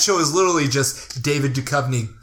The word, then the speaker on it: Duchovny: I'm more familiar with that, with David Duchovny, is that how you say his name? – That is how you say his name.